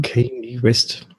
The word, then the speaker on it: Kanye West: der Rapper Kanye West, spricht man den eigentlich so aus, Patrick? Kanye West? Ja, ne, ich glaube schon. Ne? Kanye West.